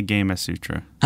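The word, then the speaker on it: Gamasutra: I thought it was Gamma Sutra, like Kama Sutra, but it is apparently Gamma Sutra.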